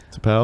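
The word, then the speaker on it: T'Pau: Tapao.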